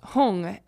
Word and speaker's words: Hum: And then um, for like Om Mani Padme Hum, they often pronounce Padme as Peme, like P M E no, P E M E, Peme, and then Hum, which is H U M. They sometimes pronounce Hung, H U N G.